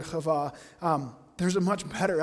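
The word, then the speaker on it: Hava (חוה): The, the, the, uh, the name Eve is pronounced Hava, which is similar um, to the, the um, Aramaic word uh, Hiwa, which is serpent.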